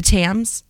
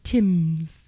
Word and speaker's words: Thames: The Thames. Thank you. It's T-H-A-M-S, and I was like, I don't know, that's not how it's pronounced, but the Thames.